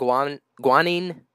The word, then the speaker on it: guanine: Is it adenine, cytosine, thymine, and guanine? – Right.